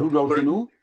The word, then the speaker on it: Rudolfinum: Rudolfinum. Do I pronounce Rudolfinum? Very good.